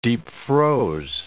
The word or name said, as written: deep-froze